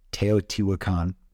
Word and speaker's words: Teotihuacan: Teotihuacan.